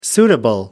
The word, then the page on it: suitable: American English